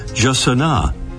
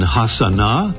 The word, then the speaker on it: jacana: About those pronunciations, the Brazilian Portuguese way is Jacana. Spanish versions include Jacana and Hasana and Hasana.